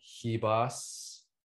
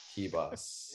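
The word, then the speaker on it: Ribas: I'm putting $3,000 on Hibas, because R's in Portuguese are pronounced with an H. Hibas.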